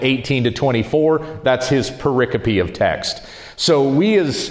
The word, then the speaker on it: pericope: And so we get together on Wednesday and we go through each one of these what's called a pericope looks like it's spelled pericope, but it's pronounced uh, pericope.